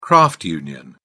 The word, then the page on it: craft union: British English